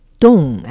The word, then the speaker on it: Dong: Dong, actually pronounced Dong. It's Dong? – Dong. – Okay.